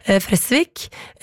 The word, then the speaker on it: Fresvik: Fordi vi var jo innom denne nydelige bygda i stad, hvor festivalen Fres blir arrangert, og jeg sa Fresvik, så kommer Oddvar på ballen her, og påpeker at det skal jo uttales med kort E, dobbelt S, Fresvik.